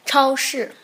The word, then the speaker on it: 超市: 超市， 네.